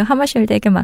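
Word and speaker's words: Hammarskjöld: 그래서 이제 제사 그 외교가의 농담이 무슨 일이 퍼지면 닭에게 맡겨 이렇게 말하는 게 유행어였다고 합니다. – 음, 닭이 아니죠? 네. – 아, 빡빡빡이 닭이요? – 아 그렇게 발음하신 것처럼 들려서. 닭에게 맡겨, 하마쉘드에게 맡겨 이렇게 말하는 게 유행이었다고 합니다.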